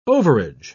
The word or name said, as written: overage